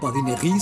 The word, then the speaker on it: badinerie: Badinerie se escribe badinerie y solemos pronunciar en castellanizado badinerie en español.